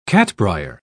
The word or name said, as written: catbrier